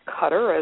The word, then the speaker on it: Qatar: And that's probably why certainly Qatar, or Qatar as it is often pronounced, Middle Eastern country, very wealthy country, a destination as well, is now going to be hosting that 2022 World Cup.